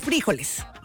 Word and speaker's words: frijoles: No, trae frijoles. – Frijoles. – Acento en la I. Frijoles.